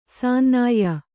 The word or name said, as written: ಸನ್ನಾಯ್ಯ